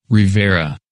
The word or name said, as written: Rivera